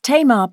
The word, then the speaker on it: Tamar: It's T-A-M-A-R.